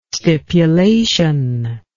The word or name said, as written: stipulation